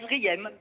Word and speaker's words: Zriem: Comment je dois prononcer Z-R-I-E-M? – Ah ben, Zrim, tout Zrim. Simplement.